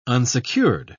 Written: unsecured

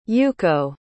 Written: youko

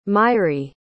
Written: mairi

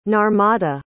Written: narmada

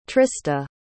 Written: trista

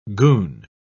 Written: goon

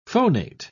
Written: phonate

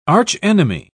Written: archenemy

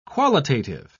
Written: qualitative

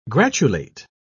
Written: gratulate

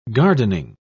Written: gardening

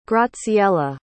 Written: graziella

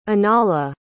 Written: anala

